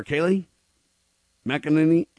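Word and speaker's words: Kayleigh McEnany: Is it Kayla or Kaylee? McEnany? – Kaylee McEnany.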